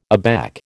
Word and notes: aback: American English

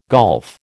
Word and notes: golf: American English

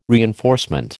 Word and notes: reinforcement: American English

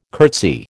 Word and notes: curtsy: American English

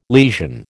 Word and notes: lesion: American English

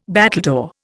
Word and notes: battledore: Indian

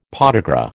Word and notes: podagra: American English

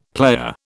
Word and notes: player: British English

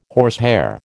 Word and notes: horsehair: American English

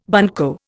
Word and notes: bunco: Indian

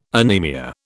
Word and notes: anemia: British English

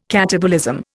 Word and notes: catabolism: Indian

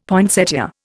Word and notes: poinsettia: Indian

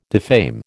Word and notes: defame: American English

